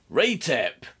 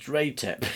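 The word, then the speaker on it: Ratep: Raytep. His name is pronounced Raytep.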